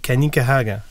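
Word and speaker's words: Kanien'kehá:ka: Mais j'ai entendu quelqu'un le prononcer. – Oui. – Puis c'était une personne issue du premier peuple, puis elle disait Kanikahaga.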